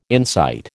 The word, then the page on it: insight: American English